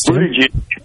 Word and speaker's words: Buttigieg: Yes, Buttigieg. Nice job. I thought for sure he was going to do it. – Oh, man. – I did. I thought. So Buttigieg, Kamala Harris, your girl.